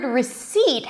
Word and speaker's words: receipt: Now, the word receipt has a P in the spelling, but there is no P sound. The P is not pronounced.